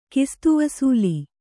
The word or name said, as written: ಕಿಸ್ತುವಸೂಲಿ